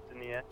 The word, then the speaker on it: Denius: His name is Denius, I believe it's pronounced. It looks like Dennis. It's pronounced Denius.